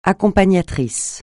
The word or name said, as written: accompagnatrice